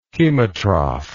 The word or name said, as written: chemotrophic